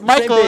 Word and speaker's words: Mikal: I didn't say Michael Bridges. Whatever. – No, it's Michael. It's like Mikal. Whatever.